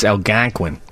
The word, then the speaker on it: Algonquin: I'm not sure about that pronunciation of Algonquin. It's Algonquin.